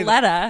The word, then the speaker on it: Valletta: Well, that's what scares me is that some of these hanger-ons, like, you know, Rob Valletna that we're going to see this season. How do you say? – Valletta. Hello. Valletta, sorry.